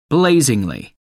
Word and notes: blazingly: American English

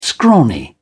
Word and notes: scrawny: British English

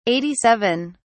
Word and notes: 87: British English

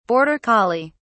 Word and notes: border collie: British English

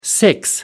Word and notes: six: American English

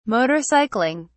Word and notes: motorcycling: British English

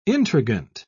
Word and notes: intriguant: British English

